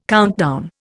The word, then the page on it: countdown: Indian